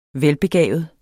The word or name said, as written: velbegavet